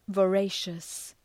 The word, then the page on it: voracious: vəʋ’reıʃəs